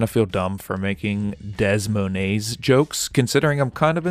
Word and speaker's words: Des Moines: Now let's take a beer break and talk about the name Des Moines. We've joked about the silent S's a few times throughout these Des Moines episodes.